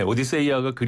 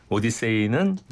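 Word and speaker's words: 오디세이아가 (Ὀδύσσεια): 그러니까 주인공은 오디세우스고 작품 이름은 오디세이, 오디세이아 이렇게 되는데 오디세이아가 그리스 원어에 충실한 발음이라면 오디세이는 영어식 발음이다 이렇게 보시면 되겠습니다.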